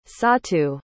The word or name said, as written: sautu